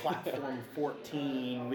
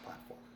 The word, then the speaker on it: PLT4M: That being said, we get PLT4AM, we get Platform 14. It's bizarre, the different combinations we get, but it's PLT4M, and it's pronounced Platform.